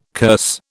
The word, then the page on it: curse: British English